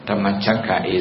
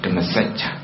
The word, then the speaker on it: Dhammacakka: Dhammacakka is a Sri Lankan pronunciation. In Burmese translation, pronunciation is called Dhammacakka. Dhammacakka and compassion. In Thai translation, it's called Dhammacakka and compassion.